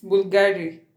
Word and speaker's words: Bulgari: Yeah, it's Bulgari.